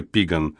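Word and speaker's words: Piegan: Примечание: черноногие, английский Blackfeet, черные ноги, союз из пииганов, английский пииган, произносится пиган,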